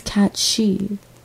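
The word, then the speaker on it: cat sìth: I refer to him as a Sith cat, but I think the original Scottish or Irish pronunciation is cat she.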